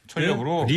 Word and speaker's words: 철렵: 니은 그 젖변으로 인해서 천엽으로 쓰고 발음할 때는 철엽으로? – 니을 동화가 돼서 철엽 이렇게 읽는 거야.